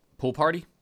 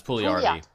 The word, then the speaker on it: Pouliot: Pool party? No, it's Pouliot. – I always thought it should be Pouliot.